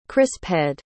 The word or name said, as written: crisphead